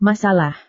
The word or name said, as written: masalah